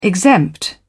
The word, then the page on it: exempt: British English